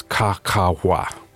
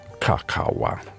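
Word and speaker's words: cacahua: And they pronounced it as three sounds. Ca-ca-wa. Ca-ca-wa was the original phonetic.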